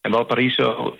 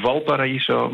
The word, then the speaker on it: Valparaíso: Ja, ik, ik zeg dan ook voor het gemak maar even Valparaiso. Maar eh, in het goed Spaans mag je die V natuurlijk niet zo uitspreken.